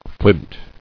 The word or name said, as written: Flint